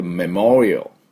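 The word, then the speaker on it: memorial: で、メモリオ、メモリオっていうふうに発音します。で、 L は最初ね、あの、青。ね、あ、るっていう、あの、最後に U をつけない。メモリオ、 L です。最後。終わり。 L で終わり。るで終わらないようにね。 U で終わらないように。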